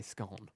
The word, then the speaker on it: scone: Donna says, definitely the Cornish way, and scone or scone, however you say it, rhymes with gone. So Donna would say scone.